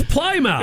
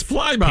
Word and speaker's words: Plymouth: And why don't they pronounce it like I did as a kid when I first read the word Plymouth? It's Plymouth. – It's Plymouth. – P-L-Y-M-O-U-T-H.